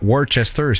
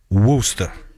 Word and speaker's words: Worcester: C'est pas Worcester, c'est Worcester. Il prononce ça de même.